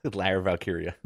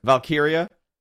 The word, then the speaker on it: Valkyria: Did I say it wrong? – Lyra Valkyria. – Valkyria. – It reminded me because a few weeks ago, a guy in our chat room said, I will give you $20 if you pronounce her name right. – That's really funny. I want it to be Lyra Valkyrie. – Yes. – I don't like Valkyria.